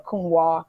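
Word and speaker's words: Counoise: They have a Cunois, which is spelled Cunoise. That I think it would be perfect for those who are still attached to their reds for the summer.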